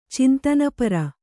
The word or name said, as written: ಚಿಂತನಪರ